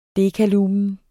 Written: dekalumen

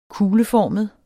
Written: kugleformet

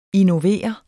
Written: innovere

